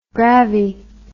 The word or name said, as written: gravi